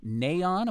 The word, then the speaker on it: NAION: It's called NAION. Am I pronouncing that correctly? NAION. Well, we call it N-A-I-O-N.